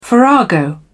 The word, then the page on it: farrago: British English